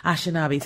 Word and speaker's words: Anishinaabe: Uh, how do you say the Anishinaabe? tribe? – Anishinaabe.